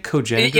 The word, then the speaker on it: Cogenitor: Am I saying that right? Cogenitor? – Yeah. No, yeah, you got it.